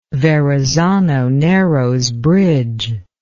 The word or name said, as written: verrazano narrows bridge